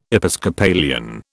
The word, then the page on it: Episcopalian: British English